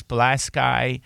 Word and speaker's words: Pułaski: I oczywiście oni mnie uczyli, jak się wymawia Pułaski, y, czy to jest Pulaskaj.